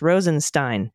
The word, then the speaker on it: Rosenstein: Rosenstein is pronounced Rosenstein. See also Diane Feinstein, Leonard Bernstein, etc.